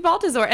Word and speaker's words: Baltezore: Hello and welcome to Soul Friend with myself, and my wonderful special guest co-host, Miss Kristy Baltezore. And I don't even know if I'm pronouncing your name right. Is it Baltezore? – You got it right.